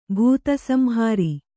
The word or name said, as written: ಭೂತಸಂಹಾರಿ